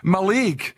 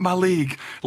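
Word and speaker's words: Mallaig: Maleg. It's called Maleg. It's called Maleg. Don't be saying Maleg.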